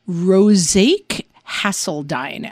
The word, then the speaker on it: Rosjke Hasseldine: But it is Rosake Hasseldine, as I understand its pronunciation.